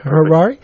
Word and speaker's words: Harary: Yeah, Harari,